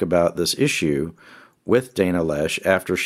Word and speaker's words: Loesch: Uh, Dana Loesch, yeah, she she's a former NRA, she, yeah.